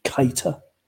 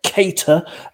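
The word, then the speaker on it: Keïta: Although one thing I will have to insist on is on Cop on Podcast, we do not tolerate the pronunciation Keita. It's okay. Keita, as in Kate Ady, Kate Moss, or Kate Winslet. Okay, and not the thing you fly.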